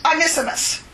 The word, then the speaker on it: Onesimus: You know, he's asking for, well, how do you say it again? Onesimus? – Onesimus.